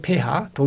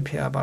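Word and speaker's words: pH: pH라고 하죠. 폐하. 독일어식으로 폐하 발음인데 폐하 7이에요.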